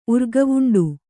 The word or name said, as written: ಊರ್ಗಾವುಂಡು